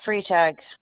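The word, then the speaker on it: Freitag: Michelle Freetag.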